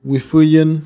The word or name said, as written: agitar